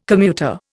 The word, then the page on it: commuter: Indian